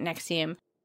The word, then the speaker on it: NXIVM: Nexium is actually spelled N-X-I-V-M, but they pronounce it Nexium.